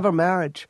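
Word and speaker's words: Mileva Marić: Do I have it? Am I pronouncing it correctly? – Yeah, Mileva Maric was a brooding,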